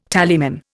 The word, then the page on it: tallyman: Indian